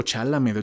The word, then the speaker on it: Chalamet: O sea, no, a Timothy Chamblet no, no sé si se dice así, o oh, Chalamet, el Timothy, el Timmy.